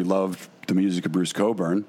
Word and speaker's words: Cockburn: Spelled Cockburn, pronounced Cockburn.